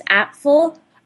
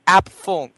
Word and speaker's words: Apfel: Iris Apfel. – Apfel, thank you.